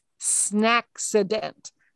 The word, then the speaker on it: snaccident: Second new vocab word for you is snaccident. Let me spell that. That's S-N-A-C-C-I-D-E-N-T. Snaccident.